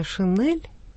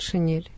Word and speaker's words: шинель: Ну, грамотные люди хорошо знают, что произнесение «шинель» — это грубая орфоэпическая ошибка. Нужно говорить «шинель».